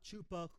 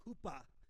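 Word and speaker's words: chuppah: It's not chupa, hoopa. And hoopa, the word, it's either spelled with a CH or with a H, because, and the hoopa, what it means is a covering.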